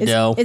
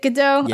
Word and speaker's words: Gadot: Gadot. – Is it Gadot? – Yeah. – Okay. – As in waiting for. – But that's good, G-O-D-O-T.